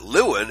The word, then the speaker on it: Lewin: It will come as no small surprise to learn that Lewin, L-E-W-I-N, and they can pronounce it Levin as well, and his team founded the Stanford Research Area.